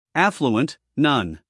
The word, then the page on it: affluent: ˈæfluənt